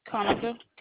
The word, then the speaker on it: Konica: Konica, K-O-N-I-C-A.